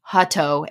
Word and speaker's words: Hutto: My married last name is apparently incredibly hard to say, even though if you just sound it out phonetically, it makes sense. – Wilson? – It's fine. Hutto. H-U-T-T-O-O.